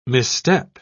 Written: misstep

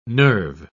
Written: nerve

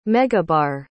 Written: megabar

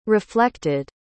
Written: reflected

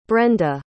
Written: brenda

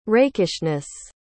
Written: rakishness

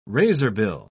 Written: razorbill

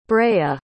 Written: brea

